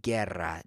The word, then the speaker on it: guerra: So the Spanish word for war is guerra, G-U-E-R-R-A. It's a silent U. It's not pronounced at all. It's not guerra, it's guerra.